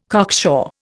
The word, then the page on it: cocksure: Indian